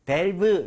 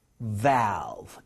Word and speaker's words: valve: 요거 발음할 때 A 발음은 턱을 많이 내려서 좀 이따가 낭독도 중요합니다. 그래서 valve 이렇게 하지 마시고요. valve 이런 식으로 많이 벌려주세요. 제가 발음 들려드릴게요. 두 번이요. valve, valve.